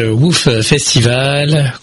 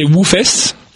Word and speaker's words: WOOFest: Eh bien, merci d'être avec nous, à continuer à échanger avec vous au sujet de ce WOOF Festival. Continuer à échanger sur le bien-être animal. C'est bien comme ça que ça se prononce. – Oui, c'est WOOFest, le vrai nom.